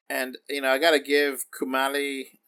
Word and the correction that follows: Kumail: Nanjiani, I'm sorry if I'm mispronouncing that, he was Kumail great.